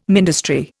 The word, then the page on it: ministry: Indian